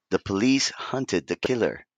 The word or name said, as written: Hunted